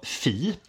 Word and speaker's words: fi: grekiskt tecken ja. eh, som uttalas då fi.